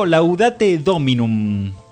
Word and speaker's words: Laudate Dominum: Estamos hoy charlando con el grupo Laudate Dominum. ¿Está bien pronunciado? – Perfecto.